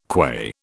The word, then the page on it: qua: British English